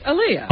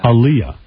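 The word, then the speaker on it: Aaliyah: Anyway, so everyone's talking about that Aliyah. How do you say it? – Aliyah. – Aliyah. I think it's Aliyah. – Oh, please.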